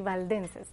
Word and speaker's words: Valdenses: The Waldensians, is that how you pronounce yes. – it? In Spanish, it would be Valdenses. Okay, Waldensians. Some were shoemakers and would go house to house, and they would also give the truth of the Lord.